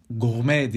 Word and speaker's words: gourmet: Gourmet diye yazılıyor. Gourmet diye okunuyormuş. Gene gourmet değişik bir şekilde.